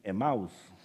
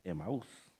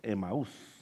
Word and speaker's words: Emaús: Algunos dicen Emaús, pero en realidad es Emaús. la pronunciación en el español, Emaús.